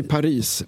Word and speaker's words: Paris: Vi säger Paris med S, inte utan S.